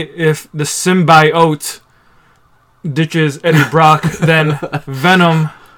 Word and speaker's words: symbiote: If he's a symbiote. – A symbiote? – Symbiote. – That's how they pronounced it in the movie trailer.